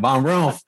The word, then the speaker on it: Von Rumpf: Yeah, you just kind of rolled it quick. Von Rumpf.